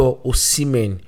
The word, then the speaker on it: Osimhen: Now, it's not Osimhen, it's not Osimhen. I'm giving you guys the actual Nigerian pronunciation. It's Victor Osimhen.